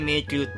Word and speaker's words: 迷宮: えー、迷うっていう字に、玉っていう字で、あの、野球の球ですね。これで迷宮って読